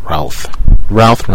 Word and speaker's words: Routh: Movie news, Superman movies coming out, Brandon Routh, Ralph rhymes with South, right?